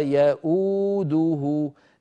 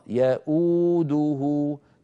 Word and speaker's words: يؤوده: أنا لما أقول يأو أنطق همزة مضمومة وبعدها واو، ولا يؤوده،